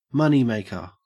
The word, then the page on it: moneymaker: Australian English